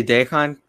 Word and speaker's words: Dejan: Dejan, am I saying it right? I need to get his name right. – I just say Dejan because Dejan Lovren from Liverpool. – Dejan.